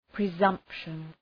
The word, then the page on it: presumption: prı’zʌmpʃən